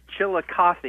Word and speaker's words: Chillicothe: And we go in Chicopee, Ohio. Is that the pronunciation? – No, sir. It's Chillicothe.